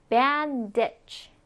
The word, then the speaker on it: bandage: 자, 발음 기호 잘 보면서 따라해 볼게요. bandage. ditch예요. 그래서 bandage. bandage.